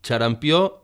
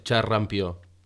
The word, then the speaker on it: xarampió: Finalment, cal tenir en compte que hem de pronunciar la R de Frederic i orquestra, i que la paraula xarampió va amb una R, no és xarrampió.